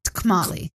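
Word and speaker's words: Tkemali: I can't pronounce it. It's got a T at the start. TK. – Kamali.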